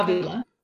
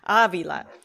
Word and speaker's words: Avila: Do I pronounce the L, Veronica? – Avila. – Okay. Avila. And she's a Los Angeles private investigator, and she was a cop, but that didn't work out.